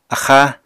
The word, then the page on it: axáa: apatani